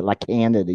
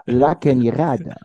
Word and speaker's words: La Cañada: Well, we have a city here called La Cañada, and it's spelled, or if you read it just straight, it's La Canada. But La Canada. – Yeah, but it's you don't say it La Canada. You say La Cañada.